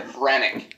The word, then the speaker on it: Hranek: Bring it all together, Hranek.